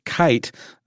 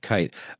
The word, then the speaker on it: Keith: Um, in 1933, when the Nazis assumed power, a man by the name of Max Kite, uh, spelled like Keith but pronounced Kite,